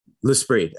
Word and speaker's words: L'Esprit: L'Esprit. Uh, people think there's like an extra I, but it's it's just L'Esprit.